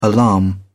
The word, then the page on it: alarm: British English